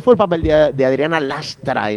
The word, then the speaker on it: Lastra: con la presencia de Adriana Lastre. – Sí, el Lastra. – Perdón, al final lo digo mal.